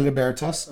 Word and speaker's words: Libertas: You know, what you've been able to accomplish with, I'm going to say Libertas. I'm going to correct myself because I'm going to pronounce it like you.